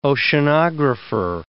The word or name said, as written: oceanographer